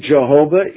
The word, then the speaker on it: Jehovah: For the Lord Jehovah is my strength. And for all the sacred namers, that's the proper pronunciation of it.